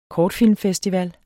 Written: kortfilmfestival

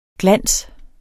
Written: glans

